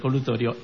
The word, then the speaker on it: colutorio: Que es el locutorio este que decía antes. – Exactamente, el colutorio.